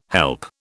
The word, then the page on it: help: British English